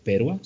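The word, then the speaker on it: Perła: Creo que la traducción en español es una perla, pero es polaca, así que Perua se pronuncia así.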